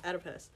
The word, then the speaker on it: Oedipus: Oedipus complex? – The Oedipus complex. – Oedipus.